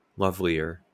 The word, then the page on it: lovelier: American English; /ˈlʌv.li.ə(ɹ)/